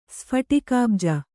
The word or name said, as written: ಸ್ಫಟಿಕಾಬ್ಜ